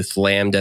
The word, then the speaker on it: Flammed: And quick shout out to him for changing his Discord username to Flammed as in slammed.